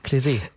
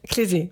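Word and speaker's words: Klez.e: Und zwar einmal von der Berliner Band Kletz. Jetzt wollte ich dich vorher noch fragen. – Klesi. – Klesi, genau. Ich habe schon wieder vergessen, wie sie ausgesprochen werden.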